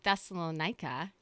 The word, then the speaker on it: Thessalonica: And so this is Paul later on, and he's writing to the people of Thessalonica.